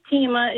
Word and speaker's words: Teima: Hi, my name is Tima. It's spelled T-E-I-M-A. And I absolutely love my name. My mom, we all have unique names. But all through school, my teachers always mispronounced my name, Taima, Tiaima, Selma.